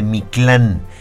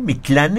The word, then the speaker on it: Mictlán: Hoy hablaste de esto de la ruta de Mictlán. ¿Lo pronuncié correcto? ¿Mictlán es correcto? – Sí. Es correcto,